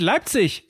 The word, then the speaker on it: Leipzig: Im Hochdeutschen oh. wird die Endung IG CH ausgesprochen. Und er wird jedes Mal wahnsinnig, wenn du Leipzig sagst. Es heißt Leipzig, soll ich dir sagen.